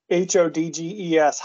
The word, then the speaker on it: Hodges: Um, so I think he's the team's leading scorer, and I think he pronounces H O D G E S Hodges.